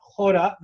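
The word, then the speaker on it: Chora: Orijinal diliyle Hora veya Kora şeklinde telaffuz edilen bu yapının tam olarak kelime anlamı nedir? – Valla iki anlamdan bahsediliyor.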